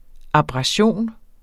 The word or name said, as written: ABRASION